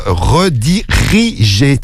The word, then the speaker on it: rediriger: Tu as oublié une syllabe. Rediriger. Tu as oublié rediger. Non, c'est rediriger sur Snap.